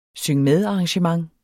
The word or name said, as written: SYNG